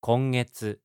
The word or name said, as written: kongetsu